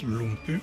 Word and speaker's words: Lumbye: Ça s'écrit Lumbye, ça paraît que ça se prononce Lumpy.